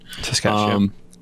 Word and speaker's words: Saskatchewan: Saskatchewan? – Saskatchewan.